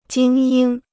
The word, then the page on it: 精英: jīng yīng